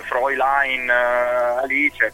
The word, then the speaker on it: Fräulein Alice: È Fräulein Alice? – Allora, no, cioè, ecco, nel senso perfetto. Che... nel senso che la pronuncia corretta in tedesco sarebbe Fräulein Alice, perché... – Ah, Alice proprio Alice, è...